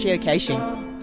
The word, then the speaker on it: geocaching: She's obviously Australian because she pronounces it right. It's geocaching.